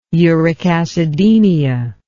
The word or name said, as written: uric acidemia